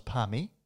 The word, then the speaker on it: parma: It's, you know, every Thursday, if it's a good, you know, local pub, it would have a pot and parma night. – Mm. – There's a bit of a, is it Victoria that pronounces it parma and New South Wales as parmi? – I'm definitely a parma.